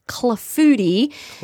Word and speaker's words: clafoutis: Now, your next recipe is for a plum clafoutis. – Clafoutis. – You said it right. Yes.